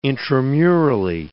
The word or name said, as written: intramurally